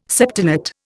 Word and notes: septenate: Indian

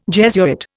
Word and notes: Jesuit: Indian

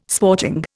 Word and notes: sporting: Indian

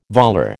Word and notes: volar: American English

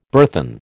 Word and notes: burthen: American English

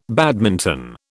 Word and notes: badminton: British English

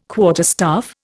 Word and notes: quarterstaff: Indian

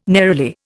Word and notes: neroli: Indian

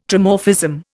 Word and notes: Trimorphism: Indian

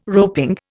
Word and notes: roping: Indian

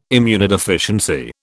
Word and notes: immunodeficiency: British English

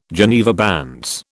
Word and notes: Geneva bands: British English

